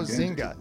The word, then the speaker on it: Zynga: Our next one, it's Zanga. Zanga Games. Does it take crypto? Does it exist? – Well, first of all, if you don't pronounce it right. – I'm oh, Zynga. Zynga. Excuse me, Zynga.